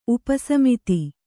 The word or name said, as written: ಉಪಸಮಿತಿ